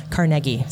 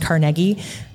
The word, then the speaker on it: Carnegie: Well, and okay, I'm going to talk about Andrew Carnegie, or depending on where you're from, Carnegie. So on the East Coast, where he is from, it's actually pronounced Carnegie, but we call it Carnegie.